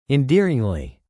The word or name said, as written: endearingly